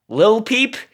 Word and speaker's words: Lil Peep: Like, am I supposed to say Lil? Lil Peep? Is that? – Yeah. – Is that what? – Lil. – No, Lil Peep's Lil.